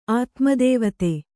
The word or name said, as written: ಆತ್ಮದೇವತೆ